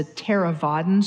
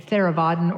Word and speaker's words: Theravadan: So there's the southern schools, which are sometimes today referred to as the Theravadan schools, which I think it's spelled T-H-E-R, E or A, V, A, D, A, N, Theravadan or Theravadan, and it means the path of the elders.